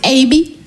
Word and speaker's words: Aby: Pour les Américains, c'est Abby. Et pour ma mère, c'est Eh, viens ici, voilà. Non, comme je vous ai dit, Abby, c'est mon prénom. Abby, A-B-Y, trois lettres, deux syllabes.